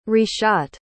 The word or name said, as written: reshot